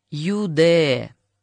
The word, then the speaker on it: Юди: Его зовут Юди. Произносится имя как Юде.